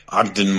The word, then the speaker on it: Ogden: Yep, Ogden Morrow.